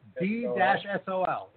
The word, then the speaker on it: D-Sol: According to Bloomberg, Goldman Sachs chief executive David Solomon was among the performers that night, spinning under the DJ name D-Soul. Oh, my God. – That's pretty embarrassing. – Or D-Sol? – Spell it. – D-S-O-L. And his name is David Solomon. – Okay. D-Sol, yeah.